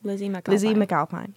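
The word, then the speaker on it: Lizzy McAlpine: Lizzie McAlpine.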